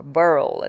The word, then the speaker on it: Burwell: And the music you were listening to was from the movie adaptation by composer Cartwell Burwell, or Burl, as you Americans say.